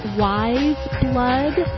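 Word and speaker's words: Weyes Blood: Drug Dealer featuring, I know it's pronounced Wise Blood, but it looks like Weyas Blood. It looks like it should be a two-syllable word.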